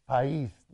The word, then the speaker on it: País: País.